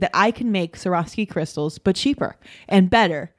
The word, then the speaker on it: Swarovski: I need to make a competitor for Swarovski crystals.